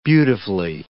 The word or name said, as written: beautifully